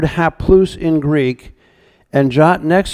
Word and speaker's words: haplous: Well, what he's saying is in the Greek word, you can write this down in your notes if you want to, it's spelled H-A-P-L-O-U-S or haplous, if you want to pronounce it in Greek.